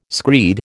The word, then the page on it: screed: American English